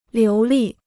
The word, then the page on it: 流利: liú lì